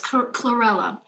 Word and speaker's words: chlorella: I was reading just recently on, I'm going to butcher the name of this, but cholera. – Yes. Oh, Yes, chlorella.